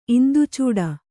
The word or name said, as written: ಇಂದುಚೂಡ